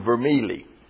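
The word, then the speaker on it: Vermigli: Calvin and a man named Vermili. The G is silent in Italian.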